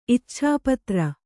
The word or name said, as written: ಇಚ್ಛಾಪತ್ರ